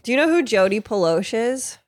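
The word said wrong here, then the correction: Plauché: That's because it's Jody Ploche.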